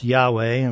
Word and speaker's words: Yahweh: And I have put up here, it's spelled YHWH, and generally pronounced Yahweh, but our translator forefathers chose to put points and vowels in there, and they called it Jehovah.